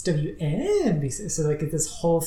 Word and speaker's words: WNBC: One of the more I guess memorable moments was he's like, when you're introducing the radio station, you have to say WNBC. You have to put the emphasis on the NBC.